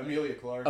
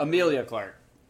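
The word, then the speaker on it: Emilia Clarke: It's Emily Clark. – Yeah, Amelia Clark. – Amelia Clark.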